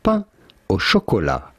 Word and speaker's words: pain au chocolat: How do you go about pronouncing it? Pain, which means bread. – Thank you. – Au chocolat. Chocolat being French for chocolate. – Thank you. – Of course, pain au chocolat.